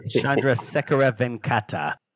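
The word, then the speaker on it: Chandrasekhara Venkata: Chandra Sekhara Venkata.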